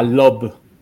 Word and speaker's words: Loab: De generación eh, de, por medio de inteligencia artificial, crea a Lob. Se escribe L-O-A-B.